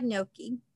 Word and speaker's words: gnocchi: I've never had gnocchi.